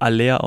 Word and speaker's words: Haller: Ich glaube, er wird Aller ausgesprochen.